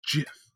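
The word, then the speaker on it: GIF: It's pronounced Jif, like the peanut butter.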